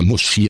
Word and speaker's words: musi: Dieses lautet auf Polnisch musi, wird aber muschi ausgesprochen.